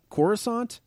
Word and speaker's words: Coruscant: And he was raised on Coruscant.